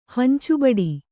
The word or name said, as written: ಹಂಚುಬಡಿ